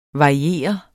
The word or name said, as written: variere